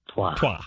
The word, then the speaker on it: toit: It's pronounced "toit,"